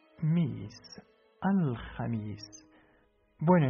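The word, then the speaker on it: jamis (خميس): Ha, mi, jamis, jamis.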